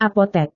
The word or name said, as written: apotek